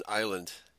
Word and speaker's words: island: The S is silent in island in English.